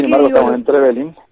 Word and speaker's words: Trevelin: ¿Trevelin o Trevelin? – No, muy bien pronunciado. Trevelin, pueblo del molino. Trepueblo, Belin, molino.